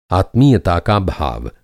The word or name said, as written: आत्मीयता